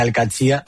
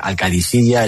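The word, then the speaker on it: Al-Qadsiah: Bueno, se suele decir Al-Qadsi, pero también hay gente que dice Al-Qadisiyya, es que es difícil, ¿eh? No es fácil, pero Al-Qadsi es lo más fácil.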